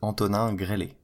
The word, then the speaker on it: Antonin Grelli: So, my real name in French is Antonin Grelli, and you can just call me Anthony because that's easier.